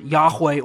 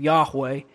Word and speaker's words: Yahweh: The reason we pronounce it Yahweh or Jehovah is the vowels were taken from Adonai and placed underneath the consonants of Yahweh to give us a pronunciation of the name.